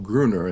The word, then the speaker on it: Grüner: It's an Austrian wine that friends affectionately call Grüner, and they spell it G-R-O-O-N-E-R.